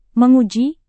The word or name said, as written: menguji